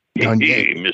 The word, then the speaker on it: Gagne: That's the way they pronounce it in Boston anyhow, but it's supposed to be a little accent goo after the E for the French Gagne. – Gagne.